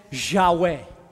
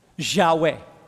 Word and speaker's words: Yahweh: So it's difficult to pronounce because it's YHWH. Try pronouncing that. The closest we can get to pronouncing that is Yahweh. Yahweh.